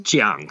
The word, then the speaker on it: Chiang: Chiang.